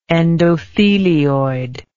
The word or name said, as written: endothelioid